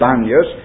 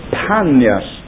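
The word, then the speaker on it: Paneas: They spell it Banyas, but it is not strictly the correct name of this place. It actually was Panyas, with the letter P rather than B.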